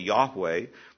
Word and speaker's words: Yahweh: It's the word that's sometimes translated Jehovah, though the better pronunciation is probably Yahweh.